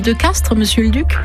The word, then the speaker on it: Castries: Quand on parle de village de Castries en Languedoc, dans la vieille langue du pays, on prononce Castres. Et comme au XVIIe siècle, cette langue était beaucoup plus parlée ici que le français, on a conservé l'usage de la prononciation ancienne.